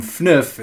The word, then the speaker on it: FNYF: So for those of you playing along at home, FNIF is spelled F-N-Y-F.